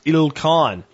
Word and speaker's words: Ilkhan: The Khan of Persia is called the Tukan, not like a Roman numeral Tukan, but it's pronounced Il Khan.